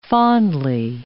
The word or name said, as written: fondly